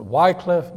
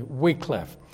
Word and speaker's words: Wycliffe: Now, some pronounce it Wycliffe, but it's more properly Wycliffe.